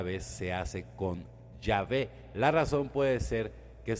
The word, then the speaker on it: Yahvé: La pronunciación más correcta, sin embargo, sería la de Yahvé.